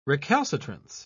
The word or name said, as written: recalcitrance